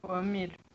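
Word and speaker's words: Joan Mir: Me corrija a pronúncia, hein? Juan Mir. – Juan Mir. – Gosto assim. Eu gosto de perguntar a pronúncia para a gente falar a coisa certinha.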